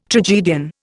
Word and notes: tragedian: Indian

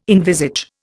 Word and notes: envisage: Indian

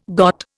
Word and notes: got: Indian

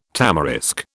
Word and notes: tamarisk: British English

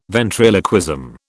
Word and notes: ventriloquism: British English

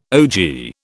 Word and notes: ogee: British English

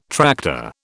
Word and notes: tractor: British English

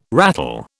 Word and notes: rattle: British English

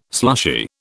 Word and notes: slushy: British English

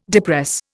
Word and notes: depress: Indian

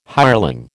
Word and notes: hireling: American English

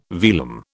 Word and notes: velum: British English

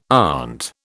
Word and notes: aunt: British English

